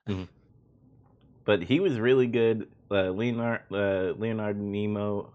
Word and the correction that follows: Leonard Nimoy: I can't fucking say his name. Spock, the guy who plays Spock. Leonard Nimoy, there we go.